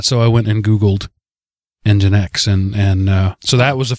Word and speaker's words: Nginx: I just found out about Nginx, is how that's pronounced.